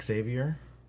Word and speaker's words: Xavier: Xavier. – Oh, so you don't say the X, you say Xavier. – Xavier. Oh, no, the X is like kind of silent.